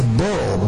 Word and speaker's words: beryl: And his body was like a burl. B-E-R-Y-L.